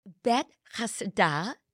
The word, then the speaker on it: Bethesda: It bet chasda, and bet always means house of.